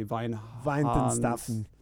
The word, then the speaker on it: Weihenstephaner: So this is, I don't know how to say the name of this brewery, Weihenstephaner. – Staffener. It's Weihenstephaner.